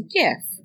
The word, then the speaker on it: GIF: Because it's a G, therefore it's a hard sound. If you wanted to be pronounced GIF like the peanut butter, you should have spelled it with a J.